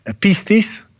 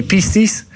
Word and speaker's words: pistis: In this context, faith is uh, the Greek word for it is uh, um, pistis. Now, I think I'm pronouncing it in the right way, pistis.